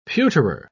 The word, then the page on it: pewterer: British English